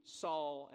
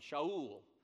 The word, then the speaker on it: Saul: Saul is actually pronounced with a SH sound, Shaul. And the Greeks didn't know how to make a sh sound.